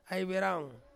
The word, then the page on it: aiveraung: Ansus